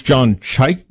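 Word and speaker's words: Chayka: How do you say John Chek? – Apparently it's John Chyka.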